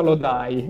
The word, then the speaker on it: Lodi: Tra l'altro Lodi si pronuncia Lodai.